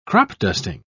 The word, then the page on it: crop-dusting: British English